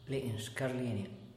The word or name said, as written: le'nshcarlenia